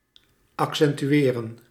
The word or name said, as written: betona